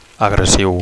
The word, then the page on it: agressiu: Catalan